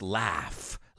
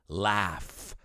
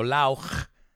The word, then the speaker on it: laugh: They can make us laugh. Let's look at the pronunciation of that word. Laugh. No lauch. Laugh.